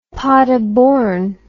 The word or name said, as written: paderborn